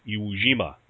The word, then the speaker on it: Iwo Jima: and the Japanese pronounced it Iwo Jima, and it means Sulfur Island.